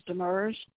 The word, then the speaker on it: Demers: My last name is Demers. – Can you spell that, please? – D-E-M-E-R-S. – I've got D like dog, D-E-M-E-R-S.